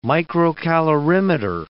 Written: microcalorimeter

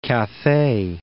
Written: Cathay

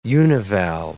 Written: univalve

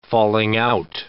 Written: falling-out

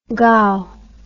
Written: gow

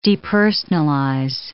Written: depersonalize

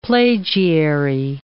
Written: plagiary

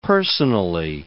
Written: personally

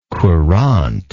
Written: courante

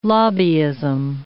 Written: lobbyism

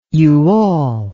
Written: you all

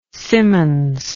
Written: Symonds

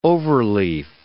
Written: overleaf

